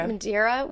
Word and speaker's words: Madeira: Am I saying that right? Madeira wine? Mm-hmm.